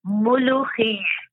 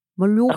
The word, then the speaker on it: Mulukhiyeh: It's Mulukhiya, that's the name. So I'm going to spell it for you because it's very hard to say. M-U-L-U-K-H-I-Y-E-H. Mulukhiya. – Mulukhiya, okay?